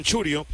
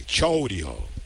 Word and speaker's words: Chourio: En inglés le llaman Churio, pero él le gusta que le digan su pronunciación completa en español. Que la O y la U se pronuncien debidamente. Chourio.